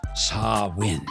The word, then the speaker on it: Samhain: But it was actually Irish, Scottish, Gaelic, pagan festival called Samhain, pronounced Sawin, which was then appropriated by another religion, and it was called All Hallows Eve, and then became All Saints Day.